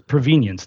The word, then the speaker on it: provenience: It wasn't provenance, it's provenience. Does anybody know that word already? – I've never heard of it. – Yeah, provenience is spelled like convenience, but with the beginning of provenance, sort of.